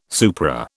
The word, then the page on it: supra: British English